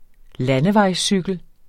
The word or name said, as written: landevejscykel